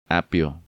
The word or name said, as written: Apio